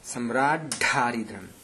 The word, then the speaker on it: सम्राड्ढरिद्राम्: सम्राड्ढारिद्रम् सम्राड्ढरिद्राम् सम्राड् तत्र डकारः द्विवारं श्रूयते खलु सम्राड्ढरिद्राम् अस्तु